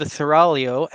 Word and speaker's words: Seraglio: Apparently, back when she was a member of the Seraglio, and I do have to note again, that is how it's pronounced. I learned this when I did a podcast on it.